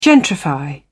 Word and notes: gentrify: British English

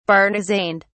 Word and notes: bartizaned: British English

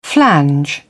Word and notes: flange: British English

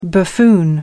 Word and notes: buffoon: American English

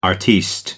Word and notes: artiste: American English

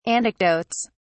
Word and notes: anecdotes: British English

